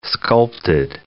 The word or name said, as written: sculpted